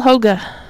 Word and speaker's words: Hoge: Will Hoge. H-O-G-E. Hoge. – How about just Hoge? – I have a friend who has a similar last name. It's B-O-G-E and it's Boga. – Well, JK's using the European pronunciation.